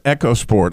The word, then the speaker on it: EcoSport: It's EcoSport. I thought it was EcoSport. Yes, I know. It's one of those words people go either way, eco or echo, but at Ford we go echo.